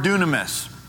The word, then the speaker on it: dunamis: We normally pronounce that Greek word dunamis. Dunamis.